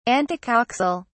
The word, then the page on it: antecoxal: British English